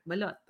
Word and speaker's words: balut: Balut. Is that how you say it? – Yes. – Balut. How do you say it? – Balut.